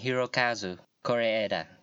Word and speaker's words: Hirokazu Kore-eda: It's a Hirokazu Koreeda.